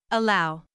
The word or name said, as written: allow